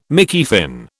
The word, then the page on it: Mickey Finn: British English